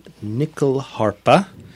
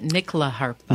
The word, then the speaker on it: nyckelharpa: And you pronounce it nickel harpa. – Nickel harpa. – Oh, like a dime, nickel, quarter.